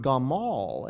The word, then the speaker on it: gamal: The Hebrew word for deal bountifully or show mercy is the word gamal. It sounds like the word for camel, but actually it's a different word.